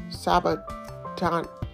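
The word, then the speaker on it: sabachthani: Sabatani Stop.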